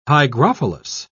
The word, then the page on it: hygrophilous: British English